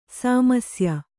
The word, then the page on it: ಸಾಮರಸ್ಯ: Kannada